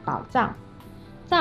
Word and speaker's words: 宝藏: 当它作为名词使用的时候，就要读作“ 藏”。比如你刚才说的“ 宝藏”，“ 藏” 是指你藏东西的地方，所以说是个名词。